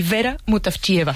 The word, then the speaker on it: Vera Mutavcheva: Zdravka Eftimova y Vera Mutavcheva.